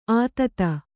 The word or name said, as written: ಅತೀತ